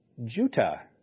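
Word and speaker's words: Jutta: He really meant a village in the hill country of Judah, or he meant a village in the hill country named Juta. Ooh, I bet you never thought of that. There is a village in the hill country of Judah named Judah, only spelled with T's instead of a D. J-U-T-T-A.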